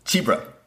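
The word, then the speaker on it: Csibra: And I'm probably going to butcher these names, but Laura Schlingloff, Cisbra, and Titone. – Chibra. – Zebra? – Chibra. – Chibra. Okay.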